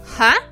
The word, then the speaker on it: huh: Word number five – ha. Ha. Это последнее слово в нашей истории. Оно пишется H-U-H.